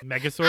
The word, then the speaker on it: Megazord: Like, because the costumes remind me of the mech swords from Power Rangers. – I see that. – Is that how you say it? – Megazords? – I'm old.